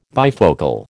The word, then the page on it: bifocal: American English